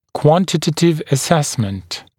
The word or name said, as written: quantitative assessment